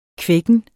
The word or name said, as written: kvækken